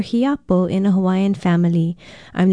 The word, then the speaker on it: Hiapo: Hiapo means firstborn. It is spelled H-I-A-P-O with no diacritical pronunciation marks, and it is used often in Hawaiian to describe the eldest child, the firstborn.